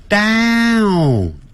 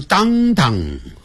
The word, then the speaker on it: downtown: 先念大小的大，大大大大，嘴巴张得好开，downtown，不要念成 downtown，不是这样的发音哦。